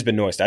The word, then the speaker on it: Benoist: It is Benoist. I said Benoit earlier. I was 100% wrong on that. – Okay. The S is silent. – It is definitely Benoist. – It's a silent S.